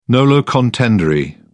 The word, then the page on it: nolo contendere: British English